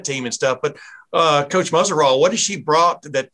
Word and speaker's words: Muzerall: Coach Muzerall.